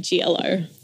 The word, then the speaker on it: Gioiello: My last name is said Gioiello. So it's like G, then yellow.